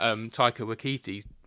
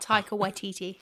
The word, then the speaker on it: Taika Waititi: And this is actually something that Taika Waititi... Oh, I've butchered that. – Taika Waititi.